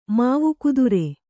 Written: ಮಾವುಕುದುರೆ

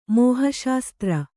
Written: ಮೋಹಶಾಸ್ತ್ರ

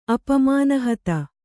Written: ಅಪಮಾನಹತ

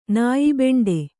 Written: ನಾಯಿಬೆಂಡೆ

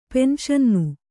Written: ಪೆನ್ಶನ್ನು

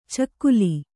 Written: ಚಿಗಿಲ್

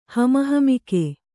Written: ಹಮಹಮಿಕೆ

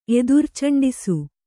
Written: ಎದುರ್ಚಂಡಿಸು

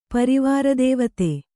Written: ಪರಿವಾರದೇವತೆ

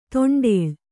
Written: ದಿಂಡಾಳು

